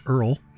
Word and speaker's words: URL: That's a URL pronounced Earl.